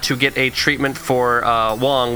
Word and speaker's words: Wong: Is it Wong? Am I saying that right? Is it... – Wong. – Yeah, okay, so it's Wong.